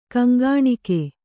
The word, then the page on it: ಕೊಂಕಣಿಗ: koŋkaṇiga; Kannada